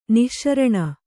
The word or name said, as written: ನಿಶಾರಣ